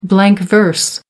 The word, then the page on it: blank verse: American English